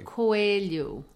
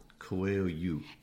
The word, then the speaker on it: Coelho: Coelho. – Coelho.